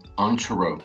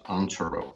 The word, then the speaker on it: Antero: Antero. So the word, it's like two words, on, Taro, like tarot cards, Antero.